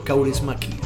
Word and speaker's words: Kaurismäki: Yes, eh, Aki Kaurismaki.